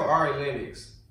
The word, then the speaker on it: Ari Lennox: They all are going through different forms of everybody hating on their ass, except for Ari Lennox.